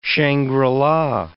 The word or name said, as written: Shangri-la